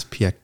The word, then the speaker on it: Piëch: How do you pronounce Piëch episode? Is it Piëch episode? – Yeah, that.